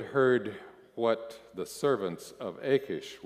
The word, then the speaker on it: Achish: The servants of Achish said, by the way, did you notice the editor of Psalm 34 got the name wrong? It's actually Achish.